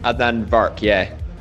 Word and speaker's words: Adnan Virk: Adnan Virk, yeah.